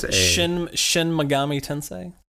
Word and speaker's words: Shin Megami Tensei: I believe I mispronounced that a little bit, but Shin Megami Tensei. – Megami. – Megami. – Megami. – Yeah. Megami. It's the South in you.